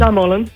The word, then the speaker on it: Stamullen: So it's Stamullen. Is that the right pronunciation? – Stamullen.